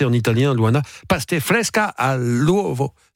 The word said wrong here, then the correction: pasta fresca all'uovo: J'étais bien parti. Je sentais que c'était bien. – Pasta all'uovo. Pasta fresca all'uovo.